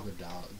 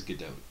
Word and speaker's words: Gadot: Yeah, Gal Gadot, Gadot, especially. It's Gadot. – Gadot, it is? – Or Gadot. – I thought it was Gal Gadot. – She says Gadot.